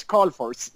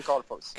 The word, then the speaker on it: Carlfors: But anyway, it's Carlfors. Göran Carlfors.